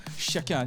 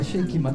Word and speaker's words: Shake It: 네, 발음을 주의하셔야 됩니다. 쉐이킷 아닙니다. 쉐이킷입니다. – 네, 섞는다고 쉐이킷 맞죠? – 네.